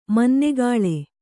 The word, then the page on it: ಮನ್ನೆಗಾಳೆ: kannada